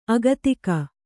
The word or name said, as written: ಅಗತಿಕ